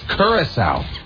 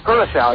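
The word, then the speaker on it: Curaçao: Curacao. – Curacao, yeah, which I always thought was Curacao, but I never knew how to pronounce it.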